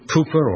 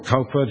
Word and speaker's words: Cowper: And William Cooper, or Cowper, depending how you pronounce his name, he felt himself to be a failure at everything.